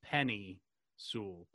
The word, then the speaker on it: Penei Sewell: I actually have um, heard it only one way right now, his first name I always hear as like Penny Pasta. So it's Penny Sewell.